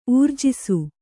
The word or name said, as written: ಊರ್ಜಿಸು